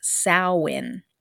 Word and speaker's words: Samhain: Halloween's origins can be traced back to the ancient Celtic festival of Samhain, spelled S-A-M-H-A-I-N, but pronounced Samhain.